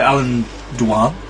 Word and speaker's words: Alan Dwan: Yeah, it's directed by Alan Dwan.